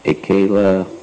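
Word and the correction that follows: Akhila: How do you say that first word again? What's the first word? Akila. Akila Rasmarita Murti.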